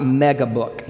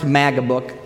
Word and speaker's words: MAGA Book: And just for the record, it's MAGA Book, not Mega Book. You'll hear it said differently. MAGA Book is a magazine cover with book pages. Mega Booking would be just a really big book. So it's pronounced MAGA Book.